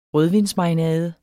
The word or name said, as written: rødvinsmarinade